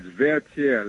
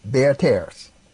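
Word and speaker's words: Vertières: It's Vertiere. Let me spell it for you. It's V-E-R-T-I-E-R-E-S. – Vertiere.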